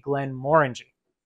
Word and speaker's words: Glenmorangie: And that's how you pronounce it, guys. Glenmorangie, not Glenmorangie, not Glenmorangie, Glenmorangie.